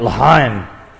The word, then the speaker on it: L'chaim: You got to put a little spit in your throat to say it right, because I'm not saying it right. L'chaim.